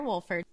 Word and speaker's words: Wolford: I am so excited because today we have Claire Wolford.